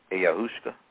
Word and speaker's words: ayahuasca: But when we deal with this issue of time, you've heard of the South American Amazonian shamans drinking a drink called ayahuasca. A-Y-A-H-U-A-S-C-A. Ayahuasca. Never heard of that? – Mm-mm. Okay, spell it again, please. – Ayahuasca. A-Y-A-H-U-A-S-C-A.